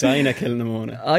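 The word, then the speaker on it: Inagh Kilnamona: Pronounce that all right? – I told you this earlier. Well, it's Ina Kilnamona.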